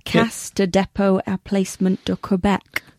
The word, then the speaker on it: Caisse de dépôt et placement du Québec: Caisse de dépôt et placement du Québec.